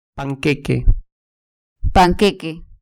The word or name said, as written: panqueque